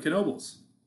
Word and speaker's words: Knoebels: Are you supposed to pronounce the N in Knoebels? – Yeah. – What about the B? – What about the O? – Yeah, every letter in Knoebels you do pronounce. Unfortunately.